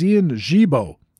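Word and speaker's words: Ian Gibaut: But first, let me tell you that the proper pronunciation of that Cincinnati reliever is Ian Gibeau, and his father was an elite ball player, a first-class cricket player on the Isle of Jersey over there in Great Britain.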